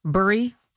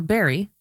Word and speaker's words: Bury: Melanie Thompson is the new president of the Townshippers Association, which hosts the Townshippers Festival next Saturday in Bury. Or Barry, depending on how you pronounce it.